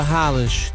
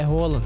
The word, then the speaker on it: Haaland: Desculpa, Haaland. Desculpa, Rola. – Que isso? – Não é o nome dele, é Rola. – Na pronúncia norueguesa.